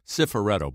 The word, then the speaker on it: Cifaretto: That uh, you know, I um, Cifaretto, by the way, Gil.